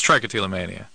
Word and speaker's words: trichotillomania: It's trichotillomania.